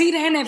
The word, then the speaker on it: Irene: And all the names, all the love that we just gave to these candidates, and I just want to pronounce Irene's name right, because I also thought she was Latina, but it's Irene Fernando. That's how you pronounce it? – Irene. It's Irene for me. – For Latina Theory, it's Irene.